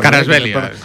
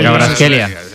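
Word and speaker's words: Kvaratskhelia: Carasbelia. – Vale, Carasbelia.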